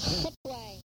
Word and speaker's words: Hutwai: Hutwai. Back X. Short I. Soft T. W, A-Y diphthong.